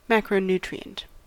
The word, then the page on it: macronutrient: American English